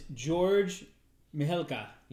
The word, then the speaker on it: Mihalka: And the director of the original, I'm going to butcher his name, but how do you pronounce that fucking name? – That is George Mihalka.